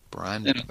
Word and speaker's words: Brian Bowdy: Bowdy rhymes with howdy, he said. That's how I knew how to pronounce that correctly. Brian Bowdy.